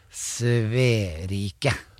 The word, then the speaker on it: Sverige: Og hvis man skal uttale Sverige riktig, ja. – Så skal man si Sverike.